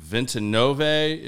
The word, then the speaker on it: Ventinove: How did you get into, you also have, is it Ventinove is your other importing where you import your spirits? – Correct. So did I say that right? – You absolutely did.